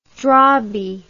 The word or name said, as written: drabi